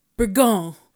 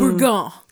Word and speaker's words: Bourgogne: And I showed it to him and he said, Bourgogne. Like that. – Mm. – Bourgogne. – There's still a nyah sound in there.